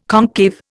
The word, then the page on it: concave: Indian